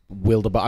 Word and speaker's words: Wildabot: I think they could have fairly easily had a big wildabot. I'm going to go with wildabot, actually. – That's how they pronounce it in the animated movie. – Okay. It's like wildabeast.